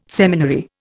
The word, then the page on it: seminary: Indian